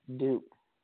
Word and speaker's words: dhoop: D-H-O-O-P, dupe. – Dupe, D-H-O-O-P.